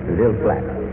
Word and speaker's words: Ville Platte: Well, sir, if you go way down in deep central South Louisiana, you hit a beautiful little city called Ville Platte. And that is spelled V-I-L-L-E, capital P-L-A-T-T-E. Did I give it the right pronunciation, Jimmy Newman? – Right. – Ville Platte.